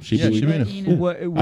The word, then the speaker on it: Shiba Inu: A baby Shiba Inu. Is that what you call him? – Shiba Inu.